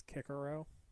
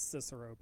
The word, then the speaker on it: Cicero: I will say the soft C because I will die on this hill where I will refuse to pronounce it as Kikero. Like, that's Cicero, bitch.